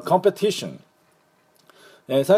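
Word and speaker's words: competition: このコンペティション、最初のアクセント、第一アクセントはですね、コンペティションなんですね。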